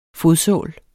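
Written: FODSÅL

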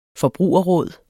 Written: forbrugerråd

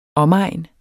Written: omegn

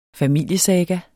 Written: FAMILIESAGA

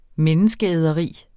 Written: menneskeæderi

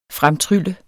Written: fremtrylle

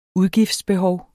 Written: udgiftsbehov

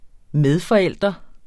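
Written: medforældre

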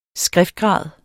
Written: skriftgrad

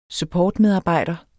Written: supportmedarbejder

